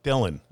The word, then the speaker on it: Dylan: Jesse Dillon. – How do you spell that? – D-Y-L-A-N.